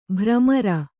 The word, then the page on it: ಭ್ರಮರ: bhramara